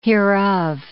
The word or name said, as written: hereof